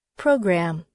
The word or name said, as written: program